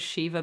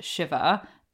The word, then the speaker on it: Shiva: was called Shiva Baby, but through watching it, I've realized that it's pronounced Shiva, and it's in reference to the basically week-long affair that happens after a Jewish funeral.